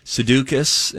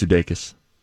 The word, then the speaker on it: Sudeikis: And then it went to, no, it's Sudeikis, and it was like, no, we're still not there. And then Grant was the voice of reason. It's Sudeikis. It's enjoyable.